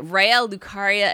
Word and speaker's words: Raya Lucaria: and now Jason and I are at the Raya Lucaria Academy.